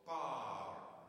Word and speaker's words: power: Er komt nog een keer terug, de power. Is dus niet bij de alten en bij twee tonen, maar is gewoon power. Ja? Dus één lettergreep is het.